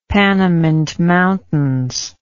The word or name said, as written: Panamint Mountains